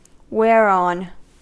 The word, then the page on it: whereon: American English